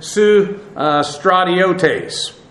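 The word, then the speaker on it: su stratiotes (συστρατιώτης): This adjective, or this noun actually, S-U-S-T-R-A-T-I-O-T-E-S. This has the idea of a soldier or one who fights, and then the soon prefix, it's actually soon, but phonetically it goes to an S there, has the idea of with, an accompaniment with.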